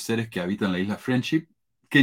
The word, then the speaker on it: Friendship: Chile se pronuncia diferente la SH. La SH en Chile es la ch y la CH es sh. Entonces, en Chile se dice friendship.